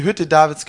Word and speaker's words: Hütte Davids: You call it Hutz David, but how do you pronounce it? – Okay. Die Hütte Davids kann das tun. – Hutte David.